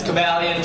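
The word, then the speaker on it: Cobalion: So some of you guys said it right. It's Cavalion, Terrakion, Virizion, and Keldeo.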